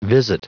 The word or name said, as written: visit